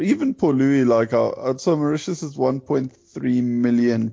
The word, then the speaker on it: Port Louis: Well, it's spelled Port Louis, but the locals say Port Louis.